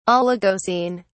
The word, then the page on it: oligocene: British English